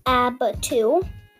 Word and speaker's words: abatu: The word of the day is abatu, which is a verb, and it's pronounced abatu.